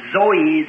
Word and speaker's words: Zoe: And the both Greek words as the life of God or eternal life is pronounced Zoe, Z-O-E, Zoe life.